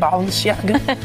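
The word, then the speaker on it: Balenciaga: La casa de moda Valenciaga, lo dije bien, Valenciaga.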